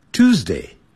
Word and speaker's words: Tuesday: Tuesday, two, Tuesday, two，有人念成 Tuesday，啊哦，在美式音标它不是这么念的，念 two, two, Tuesday, Tuesday。